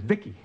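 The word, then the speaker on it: VICI: V-I-C-I, I pronounce it Vicky.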